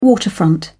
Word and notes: waterfront: British English

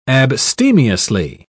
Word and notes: abstemiously: British English

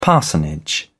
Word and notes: parsonage: British English

